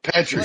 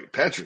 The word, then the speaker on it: Petrie: I said, what more does this kid have to do for you to get his name right, dude? – Petrie. Like, yeah, I'm sorry. Petrie.